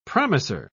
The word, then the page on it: promiser: British English